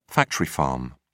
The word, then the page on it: factory farm: British English